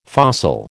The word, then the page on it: fossil: American English